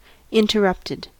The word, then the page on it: interrupted: American English